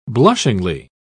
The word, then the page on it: blushingly: British English